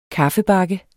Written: kaffebakke

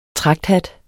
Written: tragthat

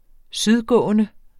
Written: sydgående

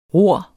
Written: ror

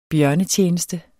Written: bjørnetjeneste

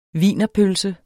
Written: wienerpølse

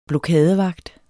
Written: blokadevagt

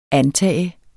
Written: antage

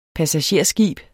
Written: passagerskib

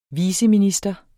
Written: viceminister

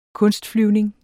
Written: kunstflyvning

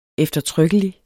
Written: eftertrykkelig

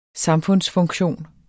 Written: samfundsfunktion

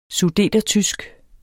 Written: sudetertysk

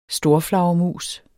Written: storflagermus

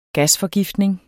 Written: gasforgiftning

